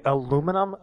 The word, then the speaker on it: aluminium: They say aluminum, aluminium? – Aluminium. – Aluminium. – They put they put like another I in there? – Well, yeah, because they have aluminum aluminances.